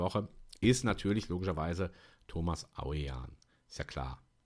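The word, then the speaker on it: Ouwejan: dass ein OU, was man im Niederländischen benutzt, AU ausgesprochen wird und ein W, zumindest in dieser Konstellation, wie ein H gesprochen wird, also nicht betont, ja. Und jetzt wissen wir alle, dass dieser Mann Thomas Aujan heißt.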